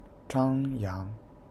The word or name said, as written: Zhang Yang